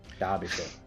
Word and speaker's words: Derbyshire: But yeah, we were at Tabletop Events in Belper, which is Derbyshire? – Derbyshire, that's it. I always get it wrong, but yeah.